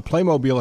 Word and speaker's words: Playmobil: And if you've never played with Playmobil, it's pronounced Playmobil.